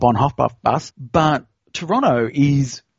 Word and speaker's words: Toronto: But even more so when I went to Toronto, did I say it right? – Yes. And what you did is you didn't say the last T, it's Toronto. That's, right. That's so Toronto.